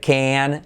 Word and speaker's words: can: Can, fan, o directamente como una E. Can, como el novio de Barbie.